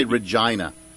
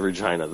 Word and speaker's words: Regina: You did say Regina. – Yes, in Regina. The city that rhymes with fun.